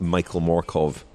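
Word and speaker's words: Mørkøv: It's as I established Michael Morkov at the Vuelta. You either pronounce both his names the Danish way, or neither.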